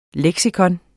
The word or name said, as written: leksikon